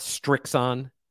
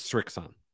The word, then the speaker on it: Srixon: There's no T in there. It's not Strixon. It's not Srixton. It's Srixon. It's not that hard.